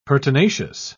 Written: pertinacious

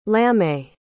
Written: lamé

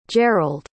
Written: gearld

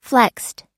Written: flexed

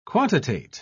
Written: quantitate

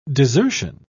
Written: desertion

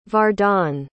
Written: vardon

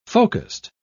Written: focused

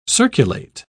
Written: circulate

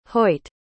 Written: hoit

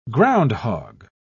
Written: groundhog

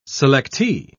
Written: selectee

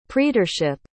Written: praetorship

